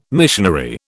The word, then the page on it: missionary: British English